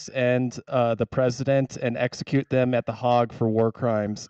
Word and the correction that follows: Hague: Secondly, it is pronounced The Hague, not The Hag. – Yes. – It's not an old woman who's going to hex you.